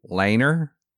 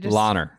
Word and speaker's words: Langer: So he won the vote over, close vote, over Bernhard Langer. – Langer. – Are getting mad at my pronunciation. – Lahner. They just Lahner. Say you don't pronounce the G.